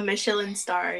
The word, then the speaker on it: Michelin: I think I'm saying that wrong. Oh, my God. I'm so bad at it. A Michelin star, yeah. – Michelin star. There you go.